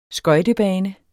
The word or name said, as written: skøjtebane